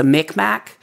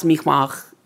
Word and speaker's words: Mi'kmaq: But you're looking at a story that was born in Nova Scotia and in the where the Mi'kmaq and the most Americans are going to think of them as the Mi'kmaq, but it's pronounced Mi'kmaq,